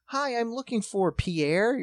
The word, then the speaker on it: Pierre: you're going to use the way we call the capital of South Dakota is spelled Pierre. – Right. – We call it Pierre.